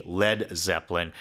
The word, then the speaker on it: Led Zeppelin: The way they spelt their name, however, to not get it confused with a lead Zeppelin, because L-E-A-D can be pronounced lead. – Right. – That's why they took out the A and they made it Led, L-E-D, Led Zeppelin, as a kind of a mocking joke to that.